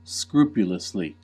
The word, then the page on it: scrupulously: American English